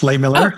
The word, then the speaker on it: Lehmiller: Did I pronounce that? – Yep, close enough. Lay Miller. – Oh, Lay Miller.